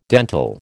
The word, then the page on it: dentil: American English